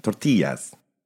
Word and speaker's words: tortillas: Right, but that is how you say that word. It's the Spanish word. The L's are silent. Tortillas.